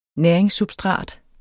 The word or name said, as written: næringssubstrat